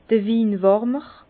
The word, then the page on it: d'r Winwärmer: Bas-Rhin; Herrlisheim